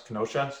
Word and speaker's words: Kenosha: Okay, so I guess let's just talk about all anybody's talking about right now is Kenosha. Is that how you say it? Kenosha? – Kenosha. Yeah, in Wisconsin.